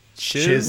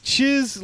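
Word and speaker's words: Chyzz: They in parentheses says rhymes with jizz. So I assume it's the first. It's C-H-Y-Z-Z. – Chiz Lily Tits. – Chiz Lily Tits.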